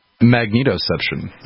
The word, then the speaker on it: Magnetoception: How do you pronounce that? Magnetic perception? – Magnetoception. – Magnetoception.